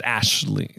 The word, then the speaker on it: Aisling: Yeah, I believe it's pronounced Ashley. Like Aisling is like Ashley. – Sure. – It is a tough, it's an Irish name.